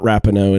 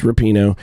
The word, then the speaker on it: Rapinoe: It's not Rapinoe, it's Rapinoe.